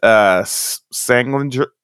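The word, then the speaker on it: Salenger: It's a movie called The Kiss, and it's with Joanna Pacula and Meredith Sanglinger. Am I saying that right? – Nailed it.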